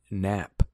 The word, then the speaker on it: Knapp: And actually confirmed today, poor little out, Greg Knapp with two P's. There's a silent K in there as well.